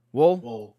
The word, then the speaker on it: Woll: Deborah Ann Woll, I think is how you say her name. – Woll.